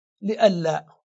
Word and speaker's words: لئلا: أن مختومة بنون ساكنة وبعدها لام بينهما إظهار، فتصير بدل أن لا ألا، وقبلها اللام لألا، فلهذا تنطق لألا وتكتب إملائيا متصلة هكذا لألا.